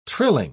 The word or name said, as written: trilling